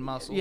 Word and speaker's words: muscles: You're not supposed to pronounce the C in muscles.